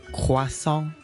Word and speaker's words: croissant: 牛角包入面咪有 OI 呢个字母嘅 ,CROISSANT。咁就係读 croissant。所以完全听到嗰个哇呢个音㗎喇。